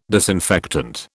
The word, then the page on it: disinfectant: British English